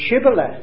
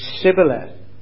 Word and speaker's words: Shibboleth: If they said, no, we're not, they said, well, say Shibboleth. But because of the dialect of the Ephraimites, they had all their lives never pronounced sh, but s, and so they ended up with Sibboleth.